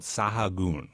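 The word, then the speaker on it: Sahagún: And the place to get it is a chocolate shop called Sahagun, with a little accent on the last U.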